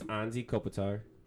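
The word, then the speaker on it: Anže Kopitar: Then it's Anzi Kopitar, if I'm saying that right.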